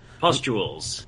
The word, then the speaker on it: Pustules: When this comes into play, I'm going to pronounce this word wrong, Pulses? Yeah. – Pustules. – Pustules on the Phoenix's majestic body suddenly contrast.